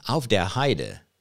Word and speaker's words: Aufderheide: And then I also am reading a book by Patricia Aufderheide, which I think is a German name, Aufderheide, which means in the meadow, but anyway, you have to pronounce it the English way.